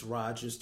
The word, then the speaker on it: Rogers: As always, you can find me on Twitter and Instagram at Justus Rogers TV, and that's spelled just the way it sounds.